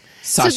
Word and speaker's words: sashiko: Some of it almost looks like, you know, sashiko mending.